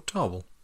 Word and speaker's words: Tawell: Tarwell. – How do you say, like, Tarwell, like a narwhal? – T-A-W-E-L-L. – Yeah. Tarwell. Yeah. – Tarwell.